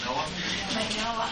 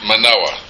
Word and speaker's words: Manoah: Manoah. – Manoah. We can agree it's Noah for the second and third syllables, right? So, Manoah.